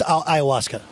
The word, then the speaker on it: ayahuasca: It's ayahuasca. I know. I've had plenty of friends who have actually done that, and there's actually a scene from Billions in which they do ayahuasca therapy and ayahuasca treatment.